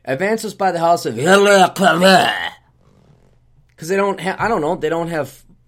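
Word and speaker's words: Veuve Clicquot: Advances, and I know it's spelled, here's how we would pronounce it with English. Advances by the House of Veuve Cliquot, okay?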